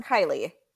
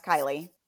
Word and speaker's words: Kaylee: Her name is pronounced Kylie. It's spelled Kaylee, but it's pronounced Kylie. Okay, let's talk about this.